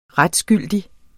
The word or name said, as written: retsgyldig